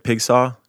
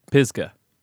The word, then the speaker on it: Pisgah: Pigsaw? – Pisgah.